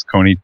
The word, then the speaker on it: Kony: Is that even how it's pronounced? Kony, K-O-N-Y? – Yes, it is.